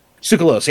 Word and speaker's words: Tsoukalos: He's one of those I would put on a short list with Georgios Tousakos. I don't know how to pronounce his last Tsoukalos. – Name, but Tsoukalos, yeah.